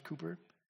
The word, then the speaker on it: Cowper: And Cooper's, it's spelt Cowper, but it's actually pronounced Cooper, wrote, "God moves in a mysterious way his wonders to perform.